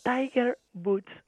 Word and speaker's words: Tiger Woods: És el de Tiger Woods Affairs.